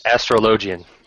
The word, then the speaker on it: Astrologian: Astrologian.